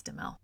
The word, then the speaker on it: Duhamel: I think it's Duhamel. I think the H is silent.